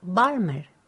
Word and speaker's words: Balmer: My name's Maria Balmer. – Can you spell that, please? – Yes. B-A-L-M-E-R.